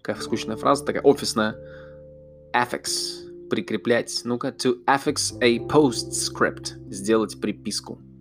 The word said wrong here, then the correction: affix: Affix. Подождите, я ударение неправильно делал.